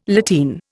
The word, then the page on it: lateen: Indian